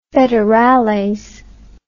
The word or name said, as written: federales